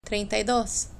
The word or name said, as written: treinta y dos